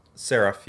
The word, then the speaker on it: Seraph: Seraph of the End. – You were actually very close. – Is it Seraph? It's Seraph, yeah.